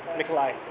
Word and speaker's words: Mikolaj: Nikolai. – Nikolai? How do you spell Mikolai? – I spell it M-I-K-O-L-A-J. Other people disagree.